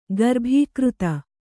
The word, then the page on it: ಗರ್ಭೀಕೃತ: garbhīkřta; Kannada